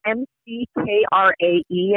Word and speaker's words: McKrae: It's spelled M C K R A E. I think it's McKrae. Okay.